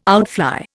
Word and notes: outfly: Indian

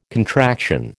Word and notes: contraction: American English

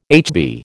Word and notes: HB: American English

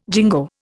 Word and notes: jingo: Indian

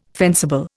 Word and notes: fencible: Indian

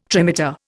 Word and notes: trimeter: Indian

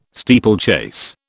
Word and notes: steeplechase: British English

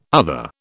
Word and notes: other: British English

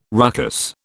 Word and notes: ruckus: British English

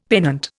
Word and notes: pennant: Indian

